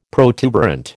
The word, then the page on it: protuberant: American English